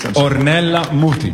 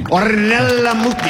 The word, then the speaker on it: Ornella Muti: I think it's Ornella Muti. I'm sorry, could you say the name properly, please? – Ornella Muti. – Ornella Muti. Of course.